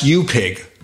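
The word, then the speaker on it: UAPIG: Curt came up with one UAP Identification Group, which would sound great, except it's pronounced U-Pig.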